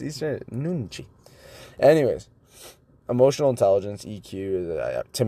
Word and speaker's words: nunchi: And that's kind of the summary of this book by Yuni Hong, The Power of Nunchi. How do you pronounce N-U-N-C-H-I?